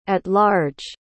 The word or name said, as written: at large